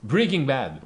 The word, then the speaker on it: Breaking Bad: Ringing Bad. Ringing Bad. – Breaking Bad.